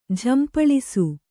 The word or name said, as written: ಝಂಪಳಿಸು